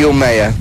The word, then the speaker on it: Umeå: So what pronunciation derivation would that result in, like? – Umeå.